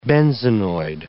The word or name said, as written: benzenoid